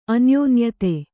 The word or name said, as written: ಅನ್ಯೋನ್ಯತೆ